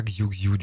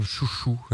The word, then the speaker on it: Xiu Xiu: L'album s'appelle Forget, et je crois, en le prononçant tout à l'heure, ça m'est revenu, qu'on prononce pas Xiu Xiu du tout, on doit prononcer quelque chose comme Chouchou, un truc comme ça.